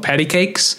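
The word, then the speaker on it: Patti Cake$: It's called Patty Cakes. – Oh yeah. – PATTICAKE $. – Yeah. – That's how it's pronounced.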